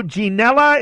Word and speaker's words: Ginella: Francisco Ginella? Is that the way to pronounce that, with the hard G? – You've nailed it.